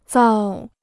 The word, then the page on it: 造: zào